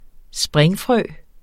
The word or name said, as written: springfrø